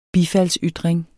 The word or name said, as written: bifaldsytring